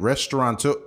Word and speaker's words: restaurateur: George Crumb, a chef and restaurateur, how do you say that? Restaurateur. It's almost like saying somebody is like a provocateur or a connoisseur, yeah.